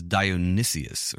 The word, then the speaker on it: Dionysius: Yeah, no, this is Dionysius or Dionysius. A judge. Judge O Dionysius. – One extra syllable in there.